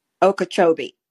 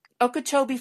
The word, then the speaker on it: Okeechobee: Okeechobee. – Okeechobee, Florida.